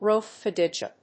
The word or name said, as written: Rhodophyta